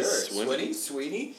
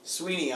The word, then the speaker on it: Swinney: Swinney? It's spelled Swinney, but some people say it's Sweeney on TV.